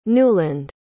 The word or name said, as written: Newland